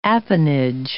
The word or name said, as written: apanage